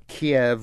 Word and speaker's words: Kyiv: They also go into some of the Ukrainian cities, Kiev versus Kyiv and things like that. – Kyiv? – Kyiv, yeah. – You can pronounce it Kyiv? – They say you can get away with that. I think that's closer to what the Ukrainians say.